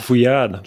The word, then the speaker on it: Fouillade: Fouillade.